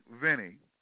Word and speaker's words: Veney: But Bethany Vinnie, and that's spelled V-E-N-E-Y, born 1812, she was born even between 1812 and 1815, and her sunset was November 16, 1915.